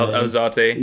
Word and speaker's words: Alzate: I have got also Stephen Alzate. I can't say his name. – Alzate.